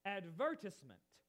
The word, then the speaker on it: advertisement: In England, for example, the word advertisement, that's the way we pronounce it, is actually pronounced advertisement, with the emphasis on a different part than we pronounce.